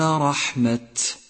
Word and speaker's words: رحمة: سادسا، تأمل كلمة رحمة رحمة في قوله تعالى أولئك يرجون رحمة الله، تأمل كيف كتبت تاؤها مبسوطة، فإذا اضطررت للوقوف عليها فقف عليها مبسوطة واهمسها، أي هكذا أولئك يرجون رحمة، فنطق ألفاظ القرآن متناسب مع طريقة رسمها.